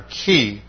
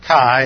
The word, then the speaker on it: chi: But that was an old pronunciation when somewhere back in the 40s they changed the whole pronunciation system of Greek, and so instead of a long I. Anybody who's taken Greek in the last 50 years has learned to pronounce this with like a long E, the letter chi. But the old way was chi,